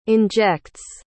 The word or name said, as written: injects